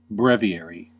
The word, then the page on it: breviary: American English